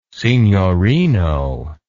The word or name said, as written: signorino